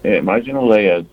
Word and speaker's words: marginalia: M-A-R-G-I-N-A-L-I-A. – Marginalia? – Marginalia is how they're saying to pronounce it here.